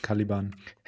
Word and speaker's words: Caliban: Können wir äh, ein für alle Mal ganz, 30 äh, Sekunden, sorry, können wir ein für alle Mal klären, ob diese Band Caliban oder Caliban ausgesprochen Caliban. werden will? Äh, Caliban.